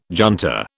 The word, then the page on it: junta: British English